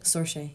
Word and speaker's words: Saoirse: Saoirse? Is that how you say it? – Saoirse, yeah.